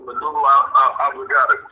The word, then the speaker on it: medulla oblongata: Yeah, the medulla oblongata. – The medulla oblongata, correct. From my understanding,